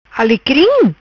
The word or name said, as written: Alicrim